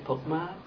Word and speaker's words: Pukma: My name is Jay Pukma, P-U-K-M-A.